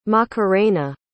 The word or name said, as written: macarena